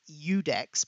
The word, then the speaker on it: Iudex: The word is Udex, but it's spelled IUDEX, and then it's underscore Phil.